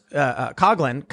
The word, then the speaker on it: Coughlin: So instead of saying something like, you know, they could say animator and comedian, you know, Seamus, Coughlin, am I pronouncing it right? – Yeah, Coughlin, you got it right.